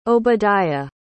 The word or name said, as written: Obadiah